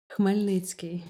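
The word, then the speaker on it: Chmelnytskyi (Хмельницький): Du bist im ukrainischen Chmelnytskyi geboren. Habe ich das richtig ausgesprochen? – Chmelnytskyi, ja, fast richtig.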